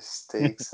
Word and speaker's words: stakes: Como é que fala? Steaks. – Eita, as. Caralho, stakes.